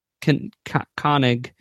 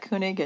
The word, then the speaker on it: Koenig: Koenig. The OE sound escaped me. It's just all it is. – I've always pronounced that Koenig and no one has ever corrected me on it. – Koenig. Suddenly it's a U sound.